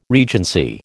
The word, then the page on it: regency: American English